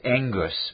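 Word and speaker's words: engus (ἐγγύς): The Greek, pronounced engus, means at hand.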